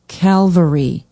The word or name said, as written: calvary